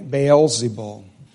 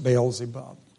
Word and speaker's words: Beelzebul: But some said, so in other words, there were some doubters, some said, he casts out demons by Beelzebul. And that's also can be pronounced Beelzebub. There's a reason why sometimes it comes out Beelzebul, sometimes Beelzebub.